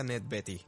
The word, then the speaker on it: Beatty: Por cierto, yo estaba diciendo Ned Beatty, pero creo que se pronuncia Ned Betty, porque lo escuché hace poco. Porque se escribe Beatty, yo pensaba que era Beatty como Warren Beatty, pero me parece que es Betty como si fuera el nombre, Betty.